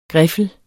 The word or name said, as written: griffel